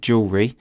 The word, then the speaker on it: jewellery: Notice the pronunciation there. Lots of people have difficulty with that word, jewellery.